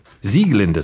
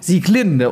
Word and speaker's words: Sieglinde: Sieglinde sehe ich hier gerade aus der Walküre, weil nämlich alles bei Wagner auf der ersten Silbe betont wird. Ja, also das wissen viele auch nicht. Nun, die alten Wagnerianer legen da sehr viel Wert drauf. – Ich mache es immer falsch. Ich mache nämlich immer Sieglinde und die Walküre.